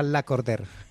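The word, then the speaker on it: Lacordaire: Es que es un nombre francés, y se escribe La Cordaire, pero se pronuncia La Cordère.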